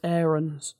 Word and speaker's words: Aarons: It's actually pronounced Aarons.